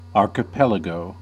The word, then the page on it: archipelago: [ˌɑːɹ.kə.ˈpɛl.ə.goʊ]; /ɑːkɪˈpɛləɡəʊ/; /ˌɑɹkəˈpɛləˌɡoʊ/